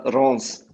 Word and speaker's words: Reims: wird das jetzt Reims oder Reims ausgesprochen? Ich bin mir jetzt nicht ganz sicher. Mein Schulfranzösisch. – Ja, es wird, es ist mir auch schwer dran, aber es wird Stade Reims ausgesprochen.